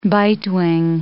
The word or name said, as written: bitewing